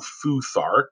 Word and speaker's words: Futhark: So the Thurisaz making the TH, you know, could be a D. You could be Futhark if you want. Some people pronounce it as a D, but it's a TH in a Thor, Odin, and things of that nature that are there.